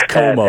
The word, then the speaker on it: Comeau: I can't pronounce that. Comeo. Oh, yeah, the, that's okay. It's Como at, at, Fort Hays.